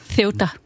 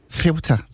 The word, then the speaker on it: Ceuta: Kan vi lige få en helt præcis udtale på plads? – Ceuta. – Ceuta.